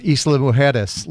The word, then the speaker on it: Isla Mujeres: I think you posted a picture from Isla Mujeres.